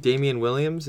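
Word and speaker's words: Damien Williams: Well, also, I really think Damian Williams, am I pronouncing it right? – Yes.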